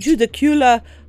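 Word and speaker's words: Judaculla: Ok, zdaj sem najdla dve različne vrste izgovorjave, en je Judakula, en je Judakula, pa oba sta ocenjena z enko, tako da še vedno ne vem, kako se to pač izgovori. Najbližji se mi je zdel en YouTube video, ki pač en pač domorodec, a ne, reče Judakula, tako da ok, Judakula rock, a ne.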